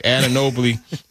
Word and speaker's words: Anunoby: Anunoby.